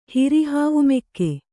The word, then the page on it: ಹಿರಿಹಾವುಮೆಕ್ಕೆ: Kannada